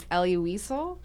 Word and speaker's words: Elie Wiesel: The 22nd introduction is that this song was inspired by the novel Night by, how do you say his name, Elie Wiesel? – Yes.